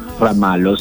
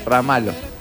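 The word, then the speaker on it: Ramalho: C se escribe con Z y E. – Serra Marro. – Ramalo, sí. – L-H-O. – L-H-O. – L-H-O, dame. – Al final. – Ramalo.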